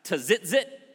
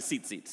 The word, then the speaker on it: tzitzit: If I have some friends here, I think we might, from the south, you might look at that and be like, is that pronounced tzitzit? But it's actually tzitzit. So you can call it tzitzit if you want, but tzitzit.